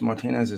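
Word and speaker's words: Martínez: It is Martinez, though, isn't it? – Yeah, it's Martinez is the correct pronunciation.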